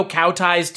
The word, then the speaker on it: kowtow: I don't think, I think if another studio kowtows to this. – Kowtows. The pronunciation is kowtow. – Kowtows. – They're not Camel, like tying them up. This isn't a rodeo, Chris.